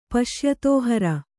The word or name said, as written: ಪಶ್ಯತೋಹರ